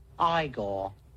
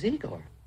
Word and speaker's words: Igor: You must be Igor. – No, it's pronounced Igor. – But they told me it was Igor. – Well, they were wrong then, weren't they? – On Surf 97.3 FM.